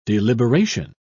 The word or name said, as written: deliberation